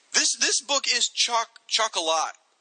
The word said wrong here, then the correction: chock-a-block: How do you say it? Chock-a-lock? What do you say? – Chock-a-block.